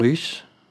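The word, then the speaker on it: Rhys: Naipaul Jean Rys, man ska uttala det så, hon kom från, bägge kommer från Västindien.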